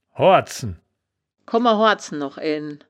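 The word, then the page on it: hoardzen: Wittlicher Wörterbuch; Wittlich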